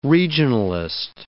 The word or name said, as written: regionalist